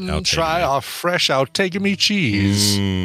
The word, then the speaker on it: Outagamie: Which provides Outagamie, apparently, is how that's pronounced.